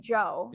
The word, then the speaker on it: Zhou: Zhou, it's actually pronounced like Joe. – Yeah. – It's like Joe.